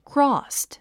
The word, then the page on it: crossed: krɔ́ːst; クロォスド